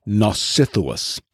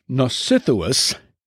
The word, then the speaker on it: Nausithous: His father was named Nausithous. And you'll hear on the island of Scyria lots of people with this same first syllable in their name, Nos, which, like Nautica, nautical.